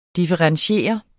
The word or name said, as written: differentiere